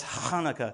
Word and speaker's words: Hanukkah: It actually starts with a CH, which makes it sound, right? So there you go. Sounds gross, but that's what it does, right? It's Hanukkah, all right? So that's how it is pronounced, Hanukkah.